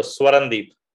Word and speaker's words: Swarandeep: So Swarandeep.